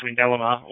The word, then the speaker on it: Windalama: You're en route to Windalama, and you will want to pronounce it as Windalama, or the locals may get a bit upset.